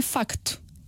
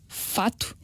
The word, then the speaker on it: facto: Em português de Portugal, pronuncia-se o C, portanto, é facto. E deve-se manter o C. Portanto, nós não podemos dizer em Portugal fato e contato quando nós queremos dizer facto e contacto.